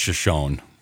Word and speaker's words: Shoshone: Shoshone, I should have gotten. Okay, fine. Shoshone County. You're right. That is just as it's spelled.